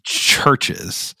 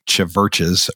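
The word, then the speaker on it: CHVRCHES: And the band is called Churches, and it's spelled with a little V in the middle, rather than a U. So, you know, it kind of looks like Chiverches or whatever.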